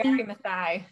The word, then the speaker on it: Wangari Maathai: Now, you had mentioned that one of your inspirations was a Kenyan woman named Wangari Maathai. Am I saying that correctly? – Wangari Maathai, yeah. – Yes.